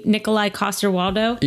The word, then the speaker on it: Nikolai Coster-Waldau: You mean for their, you mean Nikolai Coster-Waldau? – Yeah. – Did I even say that right? – Yeah, I think so.